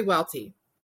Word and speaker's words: Welte: It's actually Welte.